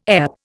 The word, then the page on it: EP: Indian